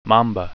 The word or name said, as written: mamba